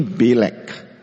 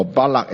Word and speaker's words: Balak: And it was King Balak, or Balak as some will pronounce it, hired Balaam to curse Israel.